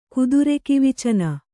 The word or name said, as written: ಕುದುರೆಕಿವಿಚನ